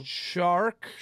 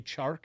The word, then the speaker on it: Chark: He can't say the C in DJ Chark. – Oh. – Shark. DJ Shark. – How do you say church? – Church. – He could say that C, but the Chark C doesn't happen.